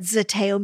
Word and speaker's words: zeteo: Let me tell you what zeteo means. It's spelled Z-E-T-E-O.